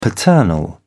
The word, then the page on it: paternal: British English